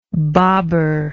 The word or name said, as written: baber